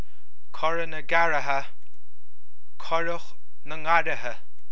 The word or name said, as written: Currach na nGarraithe